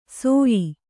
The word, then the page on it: ಸೋಯಿ: Kannada